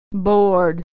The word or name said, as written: bored